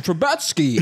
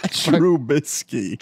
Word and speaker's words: Trubisky: How do you say it again? I swear to God, I can't. – Trubisky. – Trubisky.